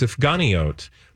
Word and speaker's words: Sufganiyot: You last brought us, dear listeners, you may not remember, but if you do, Alex brought us Sufganiyot. Wonderful donuts. – Wow. – Did I say that right? – You did.